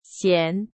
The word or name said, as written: 咸